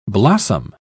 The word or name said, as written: blossom